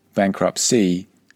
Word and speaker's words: bankruptcy: Bankruptcy is the noun. Now be careful how you pronounce these two words, bankrupt with a T at the end, and bankruptcy where you drop the T at the end.